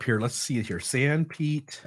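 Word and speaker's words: Sanpete: San Pete.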